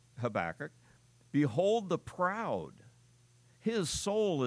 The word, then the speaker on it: Habakkuk: And I decided I like the English rather than calling the old guy a kook all the time. So I still like the English pronunciation of Habakkuk, okay?